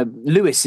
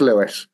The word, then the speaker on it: Lewis: So I have actually checked in with an American and said, how would you say this name? And they said, no, that's Lewis. It wouldn't be Louis as in King Louis of France or something like that.